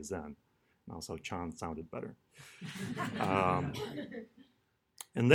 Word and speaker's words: Zen: Zen is the Japanese pronunciation of Chan.